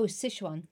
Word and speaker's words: Sichuan: If you like that much, I can't pronounce the word, the pepper. – Oh, Sichuan.